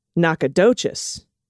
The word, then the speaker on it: Nacogdoches: The Texas sister town is pronounced Nacogdoches.